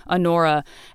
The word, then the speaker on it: Honora: On Pauline's mother, Honora, like spelled like honor with an A at the end, Honora, and